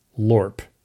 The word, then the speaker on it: LOIRP: Cowing and Wingo called the project the Lunar Orbiter Image Recovery Project, or LOIRP. They pronounced it LORP.